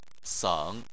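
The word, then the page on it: seng: tone 3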